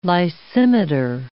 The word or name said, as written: lysimeter